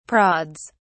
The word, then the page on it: prods: British English